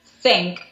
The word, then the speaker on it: Thync: Wow, I was using this device called Think, which is spelled T-H-Y-N-C.